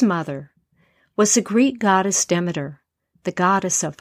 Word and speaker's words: Demeter: In this podcast, you'll hear me pronounce the goddess Demeter as both Demeter and Demeter. In Greek, the word would be Themeter. In English, it's most commonly Demeter. But in the group I told this story to, they pronounced her name Demeter.